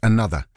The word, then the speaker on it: ANOTR: Going, despite the fact that the act is spelled A-N-O-T-R, it's pronounced another.